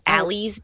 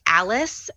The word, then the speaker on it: Alys: It's spelled A-L-Y-S. And so you'll hear a lot of people call it Allie's Beach or Allies. It's Alice, and it's named after one of the architects, chief designers of the town's, I believe mother, okay.